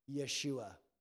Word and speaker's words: Yeshua: That word is pronounced Yeshua.